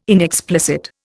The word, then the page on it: inexplicit: Indian